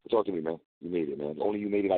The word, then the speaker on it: You Made It: Am I pronouncing that right? You Made Late? How do you pronounce it? You Mad Late? – No. You made it.